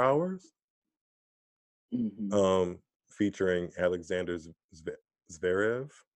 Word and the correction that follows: Zverev: I didn't think I was going to butcher his name like that. His name is Alexander Zverev.